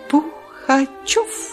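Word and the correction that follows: Пугачёв: Я неверно произношу. Пугачев.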